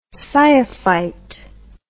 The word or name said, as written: sciophyte